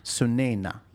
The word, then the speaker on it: Sunaina: Sunaina.